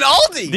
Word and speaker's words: Aldi: just type in Aldi!